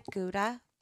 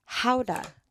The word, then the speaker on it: Gouda: Actually pronounced not like Gouda, like how we say it? – How do you say it? – Howda.